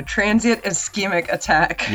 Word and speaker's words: transient ischemic attack: Transient ischemic attack.